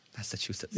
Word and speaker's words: Massachusetts: Massachusetts.